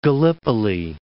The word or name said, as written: Gallipoli